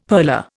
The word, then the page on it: purler: Indian